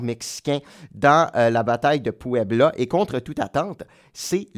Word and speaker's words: Puebla: Désolé si je le prononce pas comme du monde, mais non, c'est bon, Puebla. – Puebla, c'est ça.